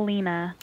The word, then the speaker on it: Lina: Paper doll, is it Lina or Lena? – Alina, paper doll Lena. – I wanted to make sure, because it's spelled L-I-N-A, right? – Yes, L-I-N-A.